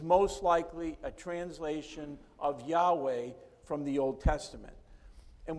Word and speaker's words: Yahweh: And this week, I chose to use the most holy and personal name of God, which we pronounce as Yahweh.